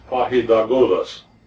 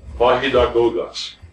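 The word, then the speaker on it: paidagogos: The Greek word for schoolmaster is pronounced pahidagogos, pahidagogos.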